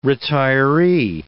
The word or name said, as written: retiree